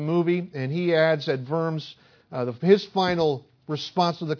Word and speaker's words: Worms: Worms is W-O-R-M-S. Worms, I believe, is how it's pronounced. Sounds better than worms.